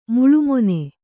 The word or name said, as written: ಮುಳ್ಳುಮೊನೆ